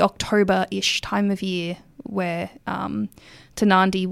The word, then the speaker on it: Tarnanthi: I wanted to talk about the Tanandi Festival, and I hope I'm saying that correctly.